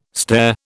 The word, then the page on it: stair: British English